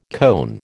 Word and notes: cone: American English

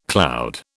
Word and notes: cloud: British English

